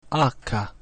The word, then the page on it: H: Spanish